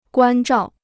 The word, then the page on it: 关照: guān zhào